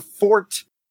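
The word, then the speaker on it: forte: Action is not the fort, and that's how it's pronounced,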